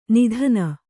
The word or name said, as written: ನೂತ್ನ